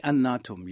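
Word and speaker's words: Eannatum: Antamena, is that how you say his name? – Anatum, yes.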